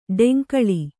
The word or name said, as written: ಡಂಗಾಳಿ